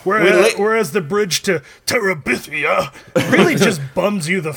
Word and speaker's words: Terabithia: Why did you say it like Gandalf? – Terabithia. – Because I think it sounds... Bridge to Terabithia. – So Bridge to Terabithia. – I think that's the better pronunciation, but...